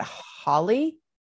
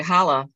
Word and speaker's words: Holle: No, it's actually Holla. Like, let me holla at your girl. – Holla at your girl. – Let me holla at your girl. – Well, it's spelled Holle, so.